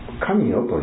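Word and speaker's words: 神代: その神よという時代があった、神様の世界と書いて神よという、ね、あるいは神様の代と書いて神よと、そういう昔々そういうその時代があったというふうに皆さんもね、なんとなくそういうふうに知っておられると思うんですね。で、今日様はその昔は神よと申し、今は任よ、元の神よになるように教えてやるとこうおっしゃってですね。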